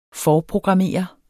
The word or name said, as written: forprogrammere